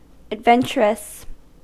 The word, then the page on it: adventurous: American English; /ædˈvɛn.tʃɚ.ʌs/